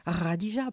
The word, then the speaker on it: Khadija: Radija qui s'écrit Khadija, qui se prononce Radija, prononce Hud, prononce Kaltoum.